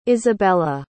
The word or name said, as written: isabella